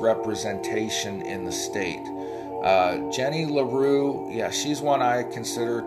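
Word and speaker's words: Le Roux: LaRue is spelled L-E space R-O-U-X.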